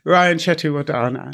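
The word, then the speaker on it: Ryan Chetiyawardana: So it is by Mr. Lion, or to be more specific, Ryan Chetty Wadi Wandana. Have I said that correctly? – Ryan Chetty-Wadana. – Yes, I think that was close.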